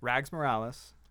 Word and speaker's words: Rags Morales: Rags Morales. Am I saying it right, Johnny? – You are.